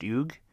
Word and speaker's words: Hugues: This involves a young man, 36-year-old man named, the name is H-U-G-U-E-S. He's French. It's pronounced Hugues de la Plaza.